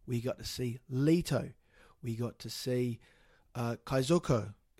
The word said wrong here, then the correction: Kaizoku: Is that how you say it? Elmas, was that how you say it? Do I look Japanese to you? So, my mistake, Elliot, that's how, Kaizoku? – Kaizoku. – Kaizoku.